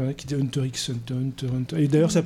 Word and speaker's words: Hunter x Hunter: Et on prononce le X si on vous emmerde. Voilà. – Ah ouais, ça se prononce pas d'habitude. – Bah apparemment, il y en a qui disent Hunter Hunter.